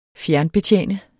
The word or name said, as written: fjernbetjene